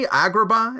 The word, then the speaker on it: Agrabah: The main city, Agrabah, is that how you pronounce yeah.